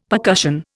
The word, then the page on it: percussion: Indian